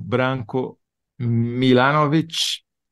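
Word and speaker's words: Branko Milanović: Branco, com um K, Branco Milanovic. Ah. Milanovic. Muito bem. O Branco Milanovic é,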